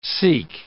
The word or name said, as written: Sikh